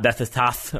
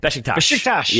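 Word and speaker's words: Beşiktaş: Corey Baird of RSL, Tyler Boyd of not Bethesdath, or however people... I've heard multiple different pronunciation on that one today. Besiktas. Yes.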